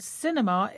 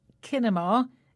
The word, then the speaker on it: cinema: And cinema is an important word in the show today because we spell it with a letter C, but we pronounce it with a S sound. So we don't say kinema, it's cinema.